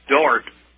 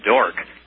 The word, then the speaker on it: Dordt: Yes, Dr. James Mahaffey, and he's a professor at Dort. I would call it Dork, but it's spelled D-O-R-D-T, College in Sioux Center, Iowa.